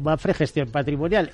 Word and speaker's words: Mapfre Gestión Patrimonial: Mapfre Gestión Patrimonial, MGP, ¿no? – Correcto, perfecto.